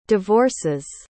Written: divorces

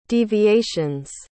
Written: deviations

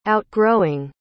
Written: outgrowing

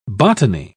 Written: botany

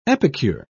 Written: epicure

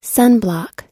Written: sunblock

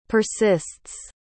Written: persists

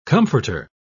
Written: comforter

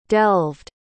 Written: delved

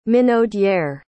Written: minaudière

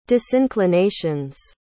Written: disinclinations

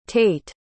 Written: tayt

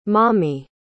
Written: mami

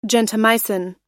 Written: gentamicin

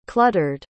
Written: cluttered

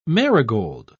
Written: marigold

